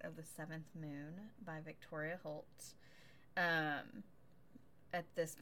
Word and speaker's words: Victoria Holt: Victoria Holt.